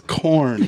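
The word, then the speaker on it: Korn: Corn, yeah.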